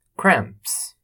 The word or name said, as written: cramps